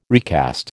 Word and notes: recast: American English

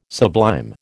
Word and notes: sublime: American English

